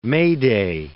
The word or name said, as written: May Day